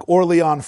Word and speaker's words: Orléans: It's written like Orleans, but it shouldn't be pronounced as New Orleans. I think it's more like Orléans, France.